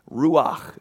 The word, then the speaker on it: Ruach: Ruach is how you pronounce it.